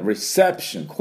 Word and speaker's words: reception: では発音の方をやっていきたいと思いますが、最初 R ですから力抜いてラ、リ、ル、レ、ロで、リ、ね、リセプション、ここにアクセントがあります。リセプションで、最後ですね、ションになりますので、リセプションではありません。